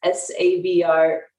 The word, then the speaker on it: Sabrena: My handle on all social media is at Sabrena Joe. And my name is spelled with an E. So that's Sabrena Joe.